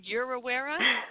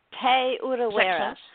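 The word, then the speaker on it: Te Urewera: Say it again. Yorawera. – Te Urawera.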